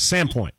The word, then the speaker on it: Sandpoint: You see, so when you say it out there, when the locals say it, they drop the D a little bit, right? So it's kind of Sandpoint.